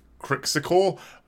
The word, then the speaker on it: Qrixkuor: I believe it's pronounced something on the lines of Crixicor, spelt Q-R-I-X-K-U-O-R.